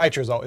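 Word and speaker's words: HRESULT: Or H result is how you say it in C++.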